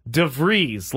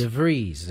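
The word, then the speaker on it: De Vries: We have the official pronunciation, and it's Leodalis DeVries, like Breeze. – With an S. – With a D-E in front of it, so DeVries. – DeVries, there it is.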